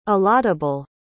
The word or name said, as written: allottable